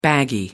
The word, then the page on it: baggy: American English